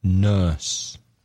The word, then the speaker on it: nurse: Again, another difficult vowel sound, nurse. Nurse. The same as in turn.